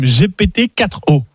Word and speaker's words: GPT-4o: Je la répète quand même, GPT-4o. – 4o. – Okay, ouais, et pas ça, 40.